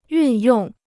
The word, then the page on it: 运用: yùn yòng